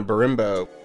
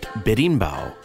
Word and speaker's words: berimbau: And we then hear a brief interlude played on a berimbo. Which is neither a guitar, drum set, nor mellotron, so we know nothing about it. What's a berimbo? – It's actually pronounced berimbau, and it is a Brazilian instrument believed to have originated in southern Africa.